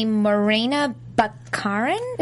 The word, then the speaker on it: Morena Baccarin: Sorry, the voice of Gideon is the one and only Morena Baccarin, is how you say it, who also moonlights as Dr. Leslie Tompkins on Gotham.